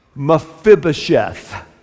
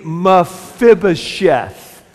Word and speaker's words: Mephibosheth: I'm talking about Mephibosheth. Say it with me, Mephibosheth.